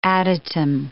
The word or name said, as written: adytum